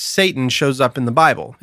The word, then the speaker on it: Satan: Huh what I discovered, and it's actually in Hebrew, it's pronounced Satan.